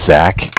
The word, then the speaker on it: Zach: My name is Zach, spelled Z-A-C-H.